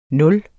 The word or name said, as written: nul